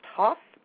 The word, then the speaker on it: Hooff: The next one is Sammy Hoff, although his name is spelled H-O-O-F-F, but it's pronounced Hoff.